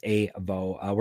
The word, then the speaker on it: AVO: It's spelled AVO, pronounced AVO.